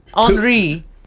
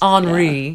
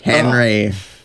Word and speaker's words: Henri: Henri. – Yes. – Henri, yeah. Please. – I'm sorry. How did you pronounce that? – Henry. Because I'm American.